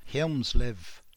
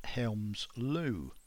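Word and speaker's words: Hjelmslev: I have already mentioned him in the earlier tape and offered an anglicization of his name, Helmslev. I think it's actually pronounced Helmsløv.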